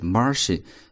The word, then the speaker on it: Martian: The Martian 是这部电影的翻译啊，Martian 注意它的读音是 Martian，本来是一个形容词，火星的或者来自火星的，所以 The Martian 就是这部电影的翻译。